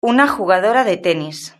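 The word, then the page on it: una jugadora de tenis: European Spanish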